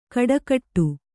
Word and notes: ಖಡಾಖಡಿ: Kannada

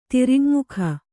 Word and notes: ತಿರಿಯಙ್ಮುಖ: tiriyaŋmukha

